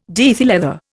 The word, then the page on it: diethyl ether: Indian